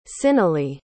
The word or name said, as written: cyneley